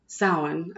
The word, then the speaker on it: Samhain: Because they say that as we get closer to Samhain, as it's pronounced, which is Halloween, the veil is thinning between this world and the other.